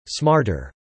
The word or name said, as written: smarter